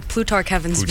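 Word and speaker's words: Plutarch Heavensbee: How do you say his name? – Plutarch Heavensby. – Plutarch, yeah. I had no idea.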